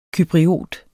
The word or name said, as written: cypriot